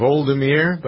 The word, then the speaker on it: Volodymyr: The difference between how to spell Vladimir Putin's first name, and it's actually, I think, is pronounced Voldemir, but it's close anyway, Zelensky.